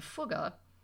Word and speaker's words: Fugger: But before you dissolve into fits of laughter over the name Fugger, note that it rhymes with took, not with duck.